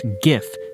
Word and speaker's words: GIF: Who says GIF? It's obviously pronounced GIF. G-I-F.